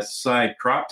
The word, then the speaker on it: Psycroptic: And then I, uh, you also turned me on to this uh, band I told you I'm enjoying a lot, uh, Psycroptic.